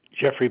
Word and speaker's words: Jeffrey: Jeffrey Benedict.